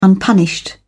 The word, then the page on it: unpunished: British English